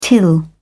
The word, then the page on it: till: British English